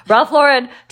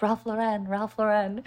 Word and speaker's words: Ralph Lauren: Ralph Lauren. Tamar keeps correcting me because it's an American name and I'm always pronouncing it like Ralph Lauren.